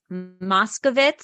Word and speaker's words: Moskovitz: It's Moskovitz.